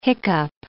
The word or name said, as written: hiccough